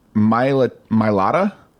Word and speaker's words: Mailata: From South Sydney, Australia, they pick Jordan Mailata.